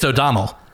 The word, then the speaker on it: O'Donnell: It's O'Donnell. So think of the E more like an, you know, like a U would be the right way to, the more right way to say it.